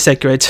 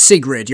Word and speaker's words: Sigrid: I'll be honest, I pronounced it Sigrid. I was going to be like, it's such a... – No, it is Sigrid, you're right. I just forgot the last letter.